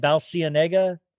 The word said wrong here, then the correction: Balenciaga: Balenciaga, Balenciaga.